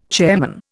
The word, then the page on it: chairman: Indian